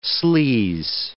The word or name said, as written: sleaze